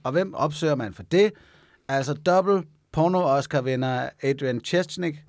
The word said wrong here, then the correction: Adriana Chechik: Adriana Chechik, nu skal jeg udtale det ordentligt, var et oplagt sted til lige at få nogle fif.